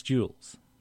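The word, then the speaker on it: Jools: a music television show hosted by Jules, pronounced Jules.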